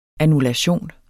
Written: annullation

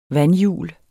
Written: vandhjul

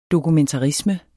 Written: dokumentarisme

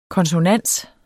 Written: konsonans

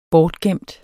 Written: bortgemt

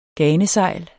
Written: ganesejl

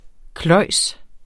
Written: kløjes

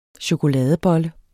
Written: CHOKOLADEBOLLE

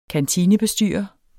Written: kantinebestyrer

